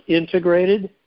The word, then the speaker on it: integrated: You send Sue, and make sure you spell this right, send her an email to integrated. I'm going to pronounce it that way, even though it's pronounced integrated. Don't put an R in there where there isn't one. Integrated. I-N-T-E-G-R-A-T-E-D. Like to integrate something. Integrated Minds. M-I-N-D-S.